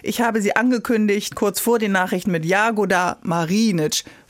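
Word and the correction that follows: Jagoda Marinić: Man sagt es Jagoda Marinic, immer kurz. – Jagoda Marinic. – Jagoda Marinic, immer Initialakzent, wie das so schön heißt. Also vorne die ersten Silben, okay. Jagoda Marinic. 95% der Kroaten beginnen mit Initialakzent.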